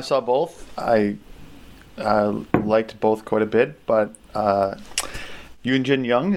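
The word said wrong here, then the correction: Youn Yuh-jung: is that how you pronounce her name? – Yoon Ya Jung.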